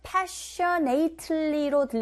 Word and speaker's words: passionately: More passionately로 들리지 않고 발음하지 않고 passionately, passionately. Passion 이란 단어에 A-T-E-L-Y 붙어서 passionately 라고 하죠. 강세 앞에 주시면 되고요.